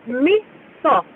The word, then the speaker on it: miso: Can you give us a Japanese pronunciation of the restaurant N-O-B-U? – Yeah, Nobu. And also, while I'm at it, you said miso. You're teasing that. It's miso.